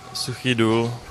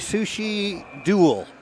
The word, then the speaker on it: Suchý Důl: Suchý Důl, Česká republika. – So my pronunciation would be Sushi Duel. And that did not sound anything like that.